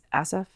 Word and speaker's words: Asaph: Asaph? – That sounds good to me.